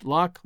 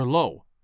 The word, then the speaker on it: lough: Lough, L-O-U-G-H, which is pronounced lock, lough, or low, to describe a lake or a river.